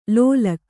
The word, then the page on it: ಲೋಲಕ್ಕು: lōlakku